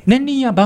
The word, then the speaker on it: 年輪家: あとこれは年輪家と書いて年輪屋と読むのかな年輪家、はいはい、年輪屋バウムクーヘン、